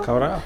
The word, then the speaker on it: Kawerau: And they've got here the biggest increase in the last decade was, hopefully I'm saying this right, Kawerau? – Kawerau, yeah.